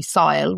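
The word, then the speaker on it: Síle: And my name is spelt, it's actually, I've butchered it a little bit. It's actually with a fada, so S-I-fada-L-E. But a lot of people call me Sile, which I am fine with.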